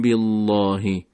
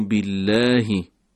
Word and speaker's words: بالله: اینجا اگر دقت بفرمایید حرف لام به صورت رقیق و نازک تلفظ میشه، نمیگیم بالله. گفتیم اگر قبل از لام کسره باشه لام به صورت نازک باید تلفظ بشه. فآمنوا بالله.